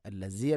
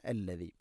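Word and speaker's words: അല്ലദി (الذي): ഹുവല്ലാഹുല്ലദീ ഹുവല്ലാഹുല്ലദി അല്ലദി, ദാലിൻ്റെ ഉച്ചാരണം ശ്രദ്ധിക്കുക. അല്ലസി അല്ല, അല്ലദി.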